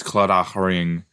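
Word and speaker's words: Claddagh: If someone's wearing a Collada ring, is it Collada or Collada? One moment. Collada. I think it's Celtic. Oh, whoa. It's Collada ring. It's... Guess how this is spelled. Because I'll tell you. It's C-L-A-D-D-A-G-H. Collada. Collada ring. Yeah, it's an Irish, it's a Celtic ring. But yeah, a few of my friends have them.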